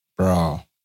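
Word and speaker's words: Andre Braugher: So it's very sad for us to lose Andre Broga. Is that how you say it? Broga. – Bra. Andre Broga. Bra.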